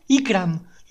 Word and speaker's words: Ykram: Mi nombre es Ikram. Lo voy a deletrear porque muchas personas me habéis preguntado cómo se escribe. Y-K-R-A-M.